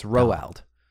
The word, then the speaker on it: Roald: Do you know it's pronounced Roald? – Roald Dahl? – We all say Roald Dahl, right. But it's two syllables. Roald. It's Roald.